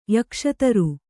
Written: ಯಕ್ಷತರು

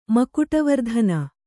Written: ಮಕುಟವರ್ಧನ

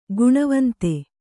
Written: ಘನವಂತ

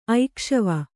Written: ಐಕ್ಷವ

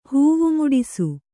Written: ಹೂವುಮುಡಿಸು

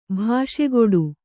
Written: ಭಾಷೆಗೊಡು

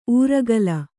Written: ಊರೆಗೋಲು

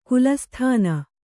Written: ಗಲಸಾಧನೆ

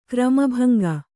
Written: ಕ್ರಮಭಂಗ

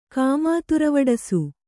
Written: ಕಾಮಾತುರವಡಸು

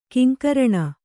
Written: ಕಿಂಕರಣ

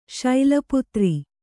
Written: ಶೈಲಪುತ್ರಿ

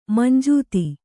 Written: ಮಂಜೂತಿ

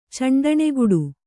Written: ಚಂಡಣೆಗುಡು

